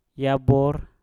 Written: yabor

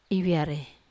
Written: via

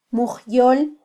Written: mujyol

